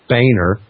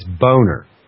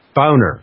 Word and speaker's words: Boehner: You know, it's not Boehner. It's Boner. It's Boner. Yeah, Boner. Anyway, it's like the Boer Wars, B-O-E-R, you know, Boer Wars, Boehm, B-O-E-H-M.